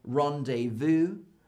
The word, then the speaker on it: rendezvous: So for example, rendezvous comes from French. And in French, they don't say the Z and they don't say the S.